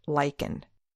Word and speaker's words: lichen: Well, actually, just the spelling and pronunciation of the word lichen. – I know where you are going with this. The spelling is L-I-C-H-E-N. The double consonant of C-H is pronounced with a K sound in this case.